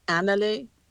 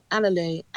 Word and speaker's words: Analu: How do you say What Are We in the language that you had to learn? – Analu. Just that one word means What Are We. Analu, Analu. – Analu.